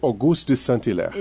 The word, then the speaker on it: Auguste de Saint-Hilaire: Auguste de Saint-Hilaire.